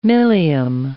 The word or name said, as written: milium